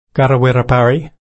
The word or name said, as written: karrawirra-parri